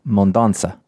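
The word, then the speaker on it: Mendonça: Chip Zdarsky, continuing his sort of slow bleed into DC, and art by Miguel Mondonaka. – The little sede on the C would indicate that it's a soft C sound, so Mondonza.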